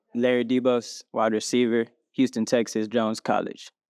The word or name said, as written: Larry DeBose IV